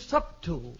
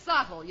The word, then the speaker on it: subtle: The word is subtle. – No, it's not. It's subtle. You don't pronounce the B.